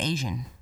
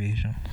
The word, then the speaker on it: Asiahn: So I think her name is Asian, but it's spelled A-S... I-A-H-N, which is what I believe it's called. – It sounds like Asian.